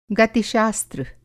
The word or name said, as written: गतिशास्त्र